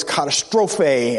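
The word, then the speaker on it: katastrophe (καταστροφή): And so that word is katastrophe, as it will be pronounced in the Greek, and that's the word that he used there in that particular context.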